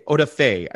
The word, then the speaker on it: Odafe: So o- Odafe, Odafe, I believe that's how the, the emphasis is on the Fe.